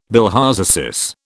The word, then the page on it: bilharziasis: British English